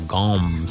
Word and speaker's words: gomme: All right, let's get into gum syrup, or gom syrup, G-O-M-M-E is the old-fashioned way to say it, or spell it.